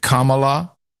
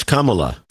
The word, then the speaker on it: Kamala: Is it Kamala? – No, it's Kamala.